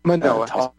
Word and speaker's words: Manoa: Lecturing the University of Hawaii at Manoa. Probably didn't say that right. – Manoa.